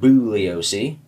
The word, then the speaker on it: Bugliosi: Bugliosi, or as it's technically pronounced, Bugliosi, described this work as his magnum opus, and good lord, it is a tome.